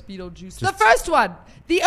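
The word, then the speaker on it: Betelgeuse: Apparently, so what happened was, if you see in the film, there's a part where they spell it B-E-T. – Yes. – B-E-E-T-L-E. G-U-E-S-E, I think. And that basically is, so you pronounce it Beetlejuice, but it's a constellation of stars.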